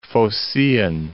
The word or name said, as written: Phocaean